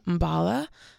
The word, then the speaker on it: Mballa: And follow me on Instagram at First Name Mbala. So that's F-I-R-S-T name. You know how to spell that. Mbala is M-B-A-L-L-A.